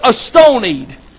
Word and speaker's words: astonied: And then the Bible said that he sat down and was astonied. That is how the word is pronounced if you look it up.